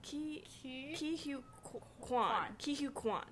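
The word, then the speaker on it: Ke Huy Quan: I'm saying who will win is Kihoo Kwan. – How do you say it again? – Kihoo Kwan.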